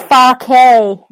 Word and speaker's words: Farke: Is it Farke? That is an interesting. He's German, right? It's Fark.